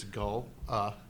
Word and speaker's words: Gullah: So it's Gullah, and that's the way it's spelled, and that's the way it's pronounced, just like Folly from last week.